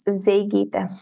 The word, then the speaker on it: Zeigyte: It's Zeigyte.